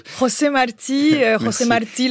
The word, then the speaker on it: José Martí: José Marti, le héros national cubain qui est omniprésent aujourd'hui encore dans l'île.